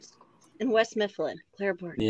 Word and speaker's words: West Mifflin: Wait, did you say that properly or did you? – West Mifflin. I said it properly.